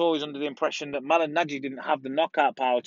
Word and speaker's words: Malignaggi: We're looking at Artem Lobov versus Paulie Malignaggi. Have I said that right? Paulie Malignaggi.